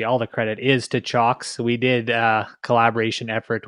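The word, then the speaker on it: Cioks: The correct Chox. Pronunciation is Chox, like a chalkboard.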